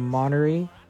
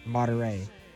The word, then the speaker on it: Monterey: Marion County up 12-0 on Monterey. I didn't say that right, correct? – Monterey.